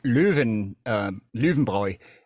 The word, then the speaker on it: Löwenbräu: And of course, you know, it's pronounced Löwenbräu in German.